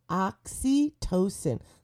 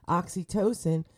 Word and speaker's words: oxytocin: And we're going to talk a little bit about oxytocin. Let me spell that for you. Google it. O-X-Y-T-O-C-I-N.